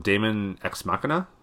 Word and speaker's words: Daemon X Machina: Uh, I don't know how to pronounce this one. Daemon X Machina? Yeah, that sounds right.